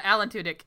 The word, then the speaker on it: Alan Tudyk: Alan Tudyk? Is that how you spell that? – Yeah, Alan Tudyk, yeah.